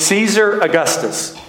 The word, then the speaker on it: Caesar Augustus: How do you say that name?" I'm still terrified of reading scripture. I'm a minister now. She goes, "Caesar Augustus."